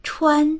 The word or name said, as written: chuan1